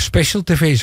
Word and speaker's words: Special TV: Wat trouwens daar wel opvallend was, nieuwkomers is Special TV. Of, ja, Special TV, zo spreek je het ook uit.